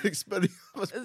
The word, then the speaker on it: Expelliarmus: Oh, I don't even pronounce it like that. – How do you say it? – Espananetusis. – What the hell? – That's how it comes in my head. – Expelliarmus, bro.